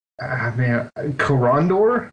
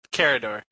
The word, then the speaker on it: Karador: So I got some EDH games in with my Karandor. I don't even know how to pronounce his name. Karandor Ghost Chieftain deck. – Karador.